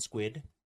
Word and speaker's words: Squid: I've been Squid. – God. – Forever. – Ever. – Ever. – Yep. So my first name is Sid, and Squid just rhymes with that.